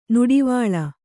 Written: ನಡುವಳಿ

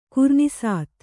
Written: ಕುರ್ನೀಸಾತು

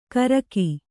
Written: ಗರ್ಗ

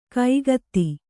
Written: ಕಾಗದ

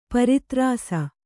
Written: ಪ್ರತಾರಿಸು

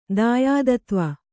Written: ದಾಯಾದತ್ವ

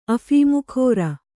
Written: ಅಫೀಮುಖೋರ

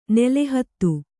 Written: ನೆಲಹಾದಿ

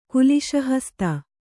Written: ಕುಲಿಶಹಸ್ತ

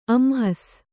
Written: ಅಂಹಸ್

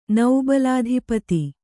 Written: ನೌಬಲಾಧಿಪತಿ

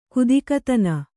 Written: ಗದಗದನೆ

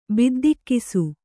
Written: ಬಿದ್ದಿಕ್ಕಿಸು

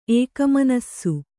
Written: ಏಕಮನಸ್ಸು

